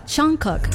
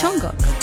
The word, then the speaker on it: Jungkook: O si no, ya me dijeron cómo se pronunciaba. Jungkook. Chongo. Como decir chongo. Jungkook.